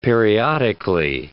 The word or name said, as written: periodically